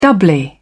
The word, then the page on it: doubly: British English